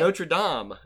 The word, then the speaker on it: Notre Dame: Okay, so they went to Notre Dame's. Meet. – Wait, hold on. You've already messed up. Notre Dame's? – How do you say it? – Notre Dame. Not Dame's.